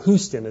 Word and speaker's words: Houston: We had a fellow out here for a little spiritual retreat we had named James Houston from Houston is how he pronounces it.